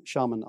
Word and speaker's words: Sharman: So I pronounce it a little bit differently, but most people pronounce it as Sharman Ultra for the toilet paper.